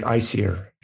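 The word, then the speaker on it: Æsir: But yeah, I always thought of it as Aesir. But if you listen to Carolyn Larrington, she pronounces it icier.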